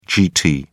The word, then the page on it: gt: British English